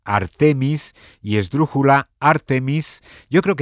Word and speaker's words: Artemis: Bueno, la duda se ha extendido y la Fundeu estos días ha aclarado que la pronunciación correcta es la esdrújula. O sea, es Artemis. – Artemis.